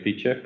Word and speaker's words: Phiture: So we just had our 1 year anniversary at Feature, but by the way, it's spelled P-H-I-T-U-R-E. It's a kind of a difficult spelling / pronunciation thing, but yeah, it's like fee with the Greek letter fee.